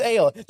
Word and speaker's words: Aiel: Just like you guys don't like their pronunciation of Tarvalon, I do not like their Aiel pronunciation. It's just Aiel. It's only four letters. It's like, you don't need to add more consonants, more emphasis to it.